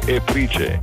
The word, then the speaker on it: ePRICE: Ma occhio che si scrive Eprice, mica siamo a Londra.